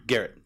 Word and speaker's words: Gehrt: Gehrt.